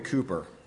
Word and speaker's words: Cowper: His name was William Cooper. His last name, by the way, is spelled C-O-W-P-E-R.